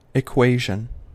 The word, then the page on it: equation: American English